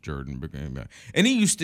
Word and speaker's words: Jordan: he would say it's pronounced Jordan, like the river, as if the river.